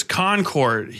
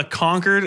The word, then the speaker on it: Concord: Concord, New Hampshire is Concord. He's not on the Concord, as in like they conquered New Hampshire. Like it's like a really cool page. It's just Concord, New Hampshire.